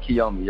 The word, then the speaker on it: Kiyomi: Is it Kiyomi? Kiyomi? – Like, yep, Kiyomi. Yep, you got it.